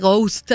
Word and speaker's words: Goust: En Bandcamp, por supuesto, Rene Ghost, así como se pronunciaría en español, G-O-U-S-T.